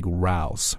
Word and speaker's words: rows: 这个地方呢说的是本周啊所见证的这个现象呢是 one of the most bizarre diplomatic rows, 是这个最奇特啊最怪异的这个外交争吵之一 ,rows, 注意在这儿它的发音啊念作 Ow, 发的是一个双元音 row, 它表示的意思呢其实就相当于是 argument, 是一个争吵或者是 quarrel。